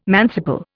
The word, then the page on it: manciple: Indian